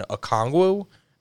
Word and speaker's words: Okongwu: between Capella and Okongwu.